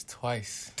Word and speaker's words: Twicee: T-W-I-C-E-E. – So don't think it's twicey. It's not. – It's twice. – Don't think it's twicey. – It's just twice.